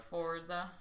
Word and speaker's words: Forza: Forza. – Forza is the correct pronunciation.